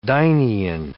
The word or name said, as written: dynein